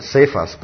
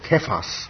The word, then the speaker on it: Kephas: And we pronounce it Cephas, but Kephas in the original language.